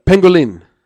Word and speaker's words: pangolin: Pangolin. – Hey, that's what I tell. And that's a cute little animal. – That's what I tell women on Friday night. Get his red meat pangolin.